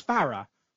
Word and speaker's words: Farrah: Yeah, no, we had Rachel Panting, who used to play for the women's team on the show, and I asked her, how do you pronounce Sophia Farrah? And she told me it was Farrah. So I was like, oh, okay. Mm-hmm. But everyone calls her Farrow.